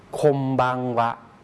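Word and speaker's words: ค ม บัง วะ (こんばんは): ไม่ได้ออกเสียงว่าบันหรือว่าบัมนะครับแต่ออกเสียงเป็นบังนะครับคมบังวะ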